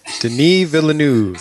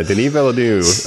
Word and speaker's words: Denis Villeneuve: Denis Villeneuve. – That's right, that Dune name. Yeah, Denis Villeneuve.